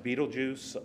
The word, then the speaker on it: Betelgeuse: And yes, that last star is pronounced Betelgeuse. You know, I don't know who named that one, but it is Betelgeuse.